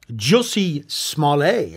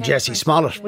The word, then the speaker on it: Jussie Smollett: It's only 90 minutes long, and it's called The Truth About Jussie Smollett, is how you pronounce his name, believe it or not. – We were all calling him Jussie Smollett. – I was calling him Jesse Smollett.